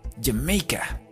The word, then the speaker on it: Jamaica: Y la tercera opción, Jamaica, que se escribe igual que en español, Jamaica, solo que recuerden que en inglés la J suena como una Y. Jamaica. Jamaica, bien gomelito. Y la respuesta correcta es que Usain Bolt is from Jamaica.